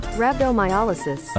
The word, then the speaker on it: rhabdomyolysis: Rhabdomyolysis.